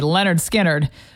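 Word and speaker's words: Lynyrd Skynyrd: One thing we know about AI, if something is spelled kind of funny, it's going to be mispronounced by AI. And so this is tricky. Leonard Skinner, we're human. That's how you say it.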